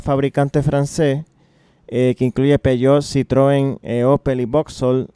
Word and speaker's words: Peugeot: Peugeot y Citroën, porque así es que lo pronuncian los franceses.